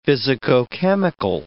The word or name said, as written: physicochemical